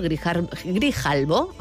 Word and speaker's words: Grijalbo: novela editada por Grijalbo, ahora sí lo he dicho bien, y escrita por Jorge Molist.